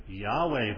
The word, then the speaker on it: Yahweh: It's probably actually pronounced something like Yahweh, but that seems quite strange to us.